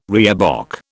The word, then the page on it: reebok: British English